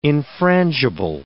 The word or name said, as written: infrangible